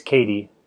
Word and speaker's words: Kadi: So it is Kadi, not Caddy. – Yeah, that's right. Yep. Kadi long A.